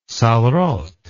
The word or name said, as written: sarraute